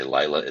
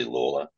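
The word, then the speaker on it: Lalor: Now, you might see his name, it's L-A-L-O-R. Most people say Layla. It's actually Lawler for the sake of getting the pronunciation right.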